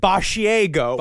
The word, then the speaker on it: Basiago: It's spelled like a mixture of barf and Asiago, the cheese. But it is he says it Basiago.